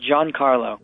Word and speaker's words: Giancarlo: I pronounced it Giancarlo.